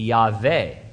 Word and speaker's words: Yahweh: And so Now, if you really want to split hairs, since we're getting deep, deep into this, you need to know that there's no W sound in Hebrew. So the most correct transliteration would use a V instead of that W, and the pronunciation would be Yahweh.